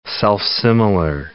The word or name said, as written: self-similar